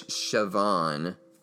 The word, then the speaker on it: Siobhan: My favorite being played by Saban. I knew I was going to screw this up. Okay. Saban. It's Siobhan Fallon Hogan. But in my defense, it is spelled S-I-O-B-H-A-N, which apparently, and I didn't know this, is the way that Irish people spell Siobhan.